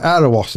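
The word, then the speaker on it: Erewash: Apparently, you pronounce it Earuhwash. Right. Did you hear that in the headphones? – Yes. – Right, let's just do it again. – All right, Earwash then.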